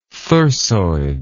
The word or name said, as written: thyrsoid